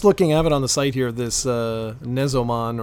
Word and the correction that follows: Nexomon: or however it's pronounced, Nexomon.